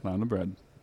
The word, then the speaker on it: Laune: L-A-U-N-E. – Yep. Launa Bread? – Launa Bread.